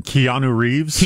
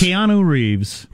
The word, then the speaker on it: Keanu Reeves: Keanu Reeves? – Keanu Reeves. – You barely got through it that time. – The very first Keanu Reeves Film Festival is going to occur.